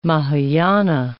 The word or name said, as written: Mahayana